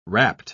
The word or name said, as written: rapt